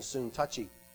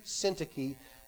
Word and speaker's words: Syntyche: affectionately call Yodia and Soon Touchy, although that is not how you pronounce her name. Paul said, I entreat Yodia and I entreat Syntyche to agree in the Lord.